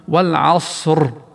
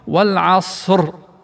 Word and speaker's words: والعصر: فكم ممن يقرؤون هذه الآية يقولون والعص، شطبت الراء، أين الراء؟ والعص، لأنه ما كرر الراء. والصح والعصر، بكر ونصف كما يقول علماء التجويد، كر ونص لأن الراء مفخمة هنا.